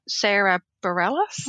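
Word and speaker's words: Sara Bareilles: Sarah Bareilles. – Yeah. That's how you say her name, I think.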